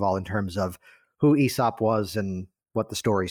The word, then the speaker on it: Aesop: So while that's brewing in the background, and you corrected me on the pronunciation, so thank you for that, Aesop.